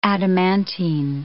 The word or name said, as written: adamantine